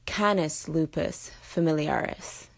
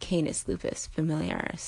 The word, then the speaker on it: Canis lupus familiaris: let you know that is Canis lupus familiaris, not Canis lupus familiaris. So Canis, like a soup can, instead of Canis, like a candy cane.